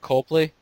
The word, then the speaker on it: Copley: Copley.